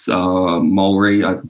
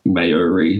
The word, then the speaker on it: Māori: He was Māori. I've heard Americans pronounce that like Maori, like native New Zealander.